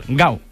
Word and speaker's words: Gal: no se debería llamar Gau, con U, que es como se llama las grasas en Brasil, sino Gal, que en portugués de Brasil se pronuncia igual, Gau, con U final.